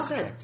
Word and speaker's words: Lysacek: How do you say the name? Do you know how? – He said it right. – Oh, Lysacek.